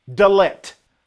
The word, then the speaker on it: dalet: For in Hebrew is pronounced dalet. Dalet, dalet.